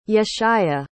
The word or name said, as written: yeshaya